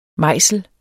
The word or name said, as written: mejsel